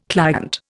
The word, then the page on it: client: Indian